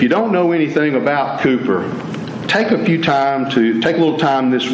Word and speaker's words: Cowper: His name was William Cowper. It's spelled Cowper. He was a British gentleman. And Cowper.